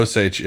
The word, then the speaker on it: Osage: We're saying that right, right? Osage? – Yep. Osage, yep.